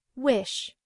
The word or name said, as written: wish